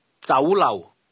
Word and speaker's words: 酒楼: The Cantonese word restaurant is pronounced with a combination of mid-rising and low-level tones. Say again, restaurant. – 酒楼。